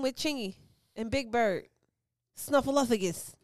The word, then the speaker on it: Snuffleupagus: Snuffleupagus.